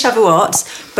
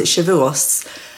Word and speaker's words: Shavuot: It is Shavuot, but older school people once upon a time would have pronounced it Shavuos. I can't even remember why this is, but like my grandmother would have said Shavuos,